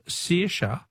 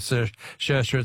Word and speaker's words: Saoirse: Helen from Footscray says, Phil, the Irish name Saoirse is pronounced either Saoirse or Saoirse, depending on which county you come from.